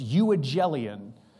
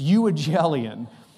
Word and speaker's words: euangelion: It's euangelion. Don't even spell it. Don't even worry about it. Just, it's euangelion.